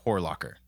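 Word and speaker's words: Horlacher: I mean, my last name is German and instead of, I mean, it's Horlocker.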